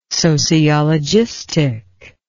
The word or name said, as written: sociologistic